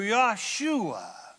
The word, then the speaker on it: Yahshua: Now that's how you say it in Hebrew, Yahshua.